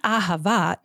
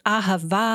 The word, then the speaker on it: ahava: It's not ahava, it's ahava, that you are supposed to carry out that last syllable as long as you can. The idea that as until you are out of breath.